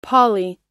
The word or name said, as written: pali